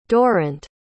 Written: durrant

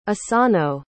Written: isano

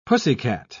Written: pussycat

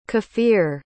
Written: kefir

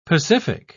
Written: pacific

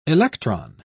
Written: electron

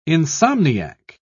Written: insomniac

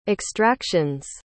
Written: extraction's

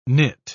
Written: knit

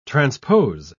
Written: transpose

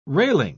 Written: railing

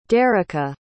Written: dericka

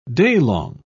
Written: daylong

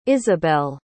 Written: isobel

